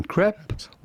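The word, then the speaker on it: crêpe: In French, we would say crepe. – Crepe.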